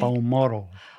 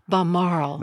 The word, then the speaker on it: Balmoral: Because she so loved Balmoral, am I pronouncing that right? – Balmoral. – Balmoral.